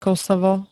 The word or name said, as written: Kosovo